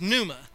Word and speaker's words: pneuma: and it was pneuma. And it's got the silent P in front of it, so I kind of like that. So it's P-N-E-U-M-A.